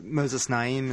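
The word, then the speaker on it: Moisés Naím: Which is to say that if you go back, the Moses Naim, is that his name? Is that how you say his name? – Yeah.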